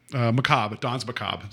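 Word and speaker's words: Danse Macabre: Don's Macabre. That's how you say it.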